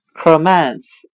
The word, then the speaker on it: commence: 今天所学的单词 commence 开始，我们就可以通过它的发音联想到汉语的客门始，客人进入店门时，工作开始了。